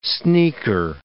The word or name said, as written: sneaker